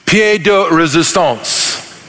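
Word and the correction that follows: pièce de résistance: Chapter 2 is, is God's pièce de resistance.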